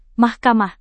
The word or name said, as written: mahkamah